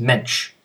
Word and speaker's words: Moench: it is pronounced Mensch, like wrench.